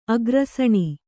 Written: ಆಕರುಸಣ

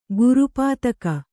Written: ಗುರುಪಾತಕ

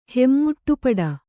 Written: ಹೆಮ್ಮುಟ್ಟುಪಡ

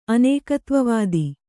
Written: ಅನೇಕತ್ವವಾದ

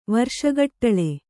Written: ವರ್ಷಗಟ್ಟಳೆ

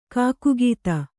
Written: ಕೈಕಾಗದ

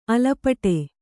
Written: ಅಲಪಟೆ

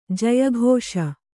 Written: ಜಯಘೋಷ

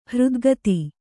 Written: ಹೃದ್ಗತ

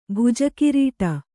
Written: ಭುಜಕಿರೀಟ

